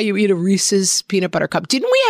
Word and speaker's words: Reese's: It's Reese's. Like, it rhymes with pieces.